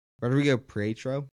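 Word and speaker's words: Rodrigo Prieto: But Rodrigo Pietro, that's how you say it, the cinematographer.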